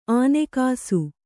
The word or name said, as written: ಆನೆಕಾಸು